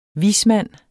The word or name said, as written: vismand